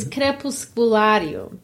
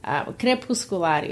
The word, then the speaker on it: Crepusculario: It's Crepusculario. Crepusculario is how you say it.